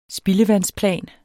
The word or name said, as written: spildevandsplan